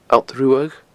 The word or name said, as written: Allt Ruadh